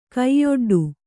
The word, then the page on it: ಕೈಯೊಡ್ಡು: kaiyoḍḍu